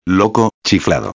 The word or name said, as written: loco chiflado